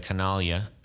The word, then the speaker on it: Canaglia: So, hey, we got the Canaglia. I'm saying it right, right? – You are saying it absolutely right.